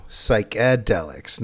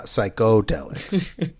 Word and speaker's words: psychedelics: It's pronounced, though, psychedelics, not leviosa, psychodelics.